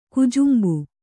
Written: ಕುಜುಂಬು

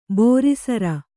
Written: ಬೋರಿಸರ

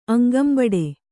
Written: ಅಂಗಂಬಡೆ

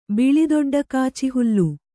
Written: ಬಿಳಿದೊಡ್ಡಕಾಚಿಹುಲ್ಲು

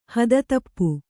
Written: ಹಾದಿತಪ್ಪು

